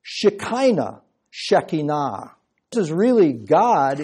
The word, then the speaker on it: Shekinah: In Greek, that word is skene, S-K-E-N-E, skene. If you were Hebrew, you wouldn't say it like that. And you would pronounce it Shekinah. Now we speak English, so we don't talk like Jews. So we say Shekinah.